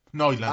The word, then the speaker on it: Neuland: Era la llamada Operación Neuland, pero como aquí es el muchacho con el grado en alemán, no sé si se pronuncia así. – Neuland. – Ah, bueno, ahí lo tiene, Neuland, la Operación Neuland.